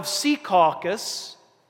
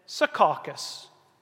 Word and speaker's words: Secaucus: No one in the town of Secaucus said Secaucus. That wasn't how you pronounced where we lived.